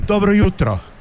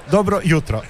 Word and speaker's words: dobro jutro: Dobro jutro. – Dobro jutro. How was that? Was that a good pronunciation? – Dobro jutro.